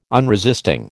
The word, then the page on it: unresisting: American English